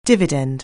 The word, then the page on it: dividend: anglais